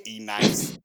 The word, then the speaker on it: Enyce: It's spelled E-N-Y-C-E. Ennis. – E-Nice. I heard E-Nice a couple times.